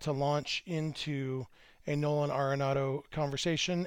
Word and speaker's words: Arenado: And once again, my goal is to get through this whole episode pronouncing his last name Arenado the way it's apparently supposed to be pronounced instead of Arenado the way I always have.